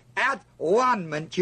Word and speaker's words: atonement: but now receive the atonement. And if you would read that word as at-one-ment, you'd understand it.